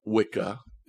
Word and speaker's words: Wicca: Now, I think most people who would adhere to Wicca, if I don't know if I'm saying that right, but you're saying it right.